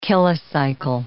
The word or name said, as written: kilocycle